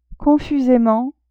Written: confusement

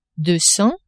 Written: deux cents

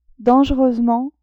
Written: dangereusement

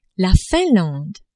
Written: Finlande

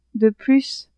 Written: de plus

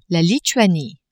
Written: Lituanie

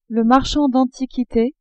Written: marchand d'antiquites

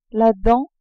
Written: dent

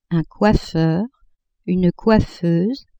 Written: coiffeur, coiffeuse

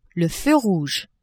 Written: feu rouge

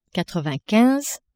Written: quatre-vingt-quinze

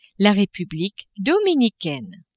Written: Republique dominicaine

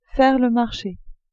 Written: faire le marche

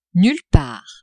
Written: nulle part

